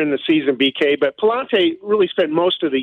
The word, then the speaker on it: Pallante: and that is Andre Pallante. Am I pronouncing that correctly there? – That's right.